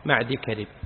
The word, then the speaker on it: معدي كرب: يجوز أن تقول وهو صحابي، طبعا نافع ليس بصحابي لكن معدي كرب صحابي، يجوز أن تقول معدي كرب ومعدي يكرب ومعدي يكرب. ذكر الحافظ في النطق باسمه عدة روايات ورجح معدي كرب، اسم مركب.